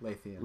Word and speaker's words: Lúthien: Lúthien.